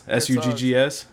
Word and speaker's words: Suggs: Is that how you say his name? Suggs? – S U G G S?